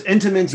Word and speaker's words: Entenmann's: So it's intimate. You don't pronounce the second N at no, all. No, it's a, it's it's a silent N.